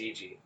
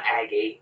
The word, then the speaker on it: Agee: A-G-E. I'm certain Aggie? It's Agee. It's not Aggie.